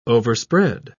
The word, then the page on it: overspread: British English